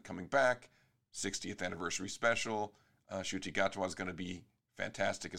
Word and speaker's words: Ncuti: Apparently it's Shuti Gatwa. It's spelled N-C-U-T-I, but it's pronounced Shuti Gatwa. That's how I've heard people saying it.